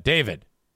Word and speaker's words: D4VD: David.